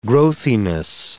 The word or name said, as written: growthiness